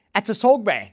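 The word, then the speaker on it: Attissogbe: Second best was 5, and that was Theo Atasogbe. – Yes. – Have I said that correctly? – Yes, I think you have.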